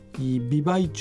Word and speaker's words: 美唄: 北千歳駐屯地のほかに美唄駐屯地、はい、これで美しい歌と書いて美唄って読むんですよね、